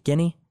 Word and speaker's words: Guinea: Guinea? – Yeah.